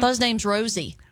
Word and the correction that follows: Rhossi: Well, it's spelled R-H-O-S-S-I, so I could have, you know, the vowel and two consonants in between another vowel. I should have probably shortened that out to Rossi and Rossi. – Made it a long O, yeah.